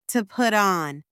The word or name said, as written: to put on